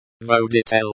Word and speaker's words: Водитель: Водитель, stress on the second syllable, the I, sounds like the E in C.